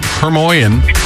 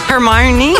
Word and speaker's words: Hermione: And Hermione Granger, and I never knew until like maybe about 3 weeks ago how to pronounce that. Hermione Granger. – Hermione.